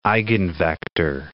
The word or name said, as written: eigenvector